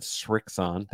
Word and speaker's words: Srixon: People don't realize it because for so many years, Srixon players, notice I said Srixon, there's no T in there.